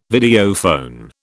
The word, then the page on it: Videophone: British English